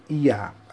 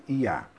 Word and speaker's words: Oia: É, chama-se IA. A gente escreve OIA, mas pronuncia-se IA.